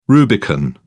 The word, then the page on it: rubicon: British English